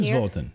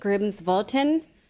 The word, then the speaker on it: Grímsvötn: Grímsvötn? This is, you see it written Grímsvötn. – Here? Grímsvötn.